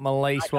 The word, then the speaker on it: Maleese: So she spelled it M-A-L-E-E-S-E, as you would say it, Melise.